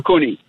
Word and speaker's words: Iwakuni: Iwakuna or Iwakuni? – Iwakuni.